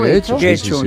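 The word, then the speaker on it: Getxo: En Guecho, que nunca sé cómo se pronuncia. – ¿Guecho? – Guecho, yo creo que lo pronuncio bien.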